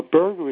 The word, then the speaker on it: Berger: While Berger is an easy name to say and spell, I've discovered it has one big problem.